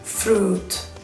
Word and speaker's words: fruit: Vale, pues no, cuando digáis la palabra fruta en inglés, borráis la I. – Y alargáis la U. Fruit.